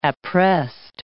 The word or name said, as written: appressed